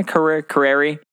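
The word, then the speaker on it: Carrere: How do you say her last name? Yeah, Carreri um, she just so happened to be like the special guest there, and she was on like the judges panel.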